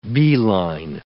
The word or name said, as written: beeline